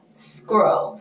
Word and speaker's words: scroll: 然后呢滑到下面就是 scroll down, 这个滑的这个动作你用滑鼠滑的这个动作也叫 scroll, 特别注意一下那个 CR 的发音 girl scroll scroll scroll up